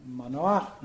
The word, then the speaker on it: Manoah: Direi che in questo racconto il padre, il futuro padre di Sansone, Manoah, no? pronuncio come si pronuncia eh, il testo ebraico, fa un po' brutta figura, perché l'angelo appare a sua moglie, non a lui.